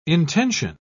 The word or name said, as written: intension